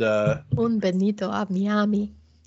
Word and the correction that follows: Bienvenidos a Miami: Sorry, Will Smith's song is in my head now. I don't even think I pronounced it right. – Bienvenidos a Miami.